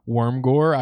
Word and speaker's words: Wormgoor: I think that's actually how you say his name, Wormgoor.